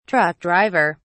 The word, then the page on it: truck driver: British English